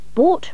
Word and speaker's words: bought: Bought, remember, rhymes with thwart.